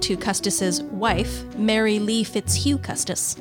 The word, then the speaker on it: Fitzhugh: Fitzhugh is like that shoe fits you. – It's pronounced that way.